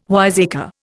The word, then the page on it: wiseacre: Indian